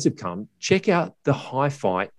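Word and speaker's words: HiPhi: And it is, in fact, pronounced Hi-Fi.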